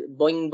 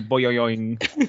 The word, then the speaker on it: boing: Let me just give you the correct pronunciation of that beginning. It's boing, boing, boing. Boing, boing, boing. Boing, boing. Oh, I'm sorry. I really thought that was boyoing.